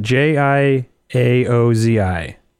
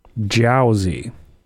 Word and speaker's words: Jiaozi: J I A O Z I. Gia I'll just keep I think, keep trying. I think you're doing great. Jiaozi.